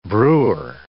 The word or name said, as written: brewer